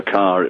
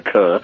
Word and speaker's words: Kerr: They kept saying Botticelli hair and Deborah Carr, it rhymes with star and all that, in case they called her Kerr.